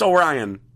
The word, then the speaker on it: Orion: It's Orion. – It's Orion like the Constellation, but it doesn't... He doesn't feel like an Orion to me.